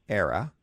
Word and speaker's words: Aira: So A-I-R-A, era. .io. So, Yuja, give us the elevator pitch for Era. And did I say that right? Is that the way you say it? – Yeah, Era, same. People pronounce it differently.